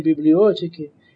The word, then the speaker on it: библиотеки: Поэт здесь употребляет старинное ударение в слове «библиотека». Так в XIX веке принято было. Вспомните Пушкина, о вы, разрозненные томы из библиотеки чертей. В XVIII веке говорили даже «библиофика» от неправильного чтения буквы «бета».